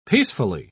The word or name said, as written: peacefully